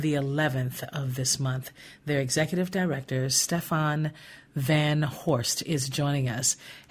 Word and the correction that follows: Van Voorst: Yeah, Stefan Van Voorst. It's two V's. – Van Voorst.